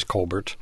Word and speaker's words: Colbert: It's so hard to stop saying Colbert because her brother Stephen is so much more famous with the mispronounced last name. But her name is Colbert. – Yep. – And Colbert. Her last name is a hyphen and then B-U-S-A.